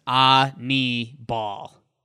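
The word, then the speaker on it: Aníbal: Just reminds me of a pretty little girl in the South, Annie Bell. It's like, wow. – Annabelle. – Annie Bell had a great game yesterday. So good for Sanchez. – You'd like the pronunciation on Baseball Reference. It says Ani-ball.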